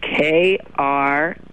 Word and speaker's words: Krautrock: The musical style incorporates experimental electronic music, jazz, and crotch rock. – What? – Crotch rock. – Can you spell that? – Krautrock. – That's pronounced krautrock. It's a German expression.